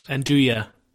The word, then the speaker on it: Andouille: Just to, there was some nuja, Is that, am I pronouncing that right? – Andouille.